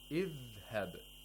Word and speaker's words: Idhhab: If the letter, the first vowelled letter has a fatha or kasra, we pronounce it with kasra. Idhhab.